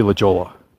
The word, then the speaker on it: La Jolla: I mean, Down East, we wouldn't know how to pronounce that California town, city, whatever it is, that's spelled L-A-J-O-L-L-A. For most people Down East, that would be La Jolla. Anybody on this side of the Mississippi, with perhaps a little more linguistic savvy, might realize that it's actually La Jolla.